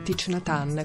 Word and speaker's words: Thich Nhat Hanh: Ma torniamo ora a Plum Village, ovvero al monastero fondato dal maestro Zen vietnamita Thich Nhat Hanh, o Thich Nhat Hanh, come si dice secondo la pronuncia vietnamita,